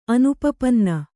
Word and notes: ಅನುಪಪನ್ನ: anupapanna

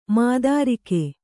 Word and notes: ಮಾತೃಕೆ: mātřke

